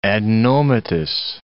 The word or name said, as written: adenomatous